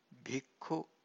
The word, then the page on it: bhikkhu: Pali